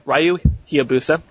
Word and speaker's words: Ryu Hayabusa: It's a bloody Re- uh, Ryu, um, Ryu Hayabusa, Hayabusa, Hi- there we go.